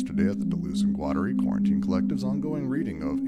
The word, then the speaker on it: Guattari: Guattari.